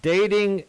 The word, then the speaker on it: Dataing: I just wanted to, because as you pronounce it, it's dating, but I want the audience to know it's spelled DATAING.AI, which means artificial intelligence, and it's a relationship matchmaking software or app.